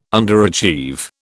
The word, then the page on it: underachieve: British English